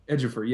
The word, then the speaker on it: Ejiofor: Ejiofor, yes.